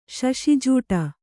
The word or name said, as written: ಶಶಿಜೂಟ